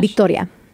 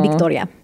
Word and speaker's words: Victoria: Although my name, Victoria, is pronounced differently in Spanish. But because it is both an English and Spanish name, I don't care how it's pronounced. – How's it pronounced in Spanish? – Victoria. – Victoria. Oh, with a B. – B, more of a B sound.